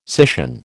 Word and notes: scission: American English

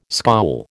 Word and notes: scowl: American English

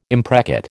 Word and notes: imprecate: American English

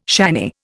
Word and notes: shanny: Indian

